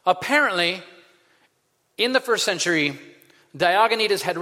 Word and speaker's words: Diognetus: And what is the guy's name? I want to pronounce it right. The guy's name is Diognetus.